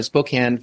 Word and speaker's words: Spokane: Did I say that correctly? Spokane? It's not Spokane, right? Spokane. – It's Spokane.